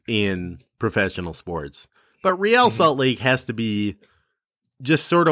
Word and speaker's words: Real Salt Lake: I'm afraid to tell you that it's Real Salt Lake, even though that would be using the Spanish pronunciation of a word of a team that has nothing to do with Spanish or the Spanish language of any kind.